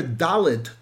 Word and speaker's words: Dalet: The letter Dalet, the way we pronounce it, is like the word Dalus, poor.